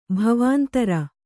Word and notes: ಭವಾಂತರ: Kannada

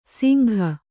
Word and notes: ಸಿಂಘ: singha

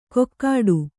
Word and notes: ಕೊಕ್ಕಾಡು: kokkāḍu

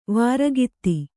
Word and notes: ವಾರಗಿತ್ತಿ: vāragitti

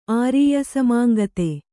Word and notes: ಆರೀಯಸಮಾಂಗತೆ: Kannada

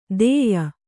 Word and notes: ಧೇಯ: kannada

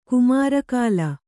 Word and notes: ಕುಮಾರಕಾಲ: kannada

